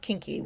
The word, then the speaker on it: Kinki: Kinki? K-I-N-K-I. Which I guess, yeah, we just pronounce kinky, which is kind of awkward in English.